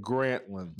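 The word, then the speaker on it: Grantland: Bill Simmons had the Gratlin. Did I say that right? The Gratlin? Is that right? – He had Grantlin. – The Grantlin, okay.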